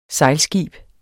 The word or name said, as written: sejlskib